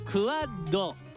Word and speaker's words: QUAD: QUAD と書いてクワッド。